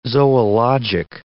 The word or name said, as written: zoologic